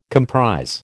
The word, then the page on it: comprise: American English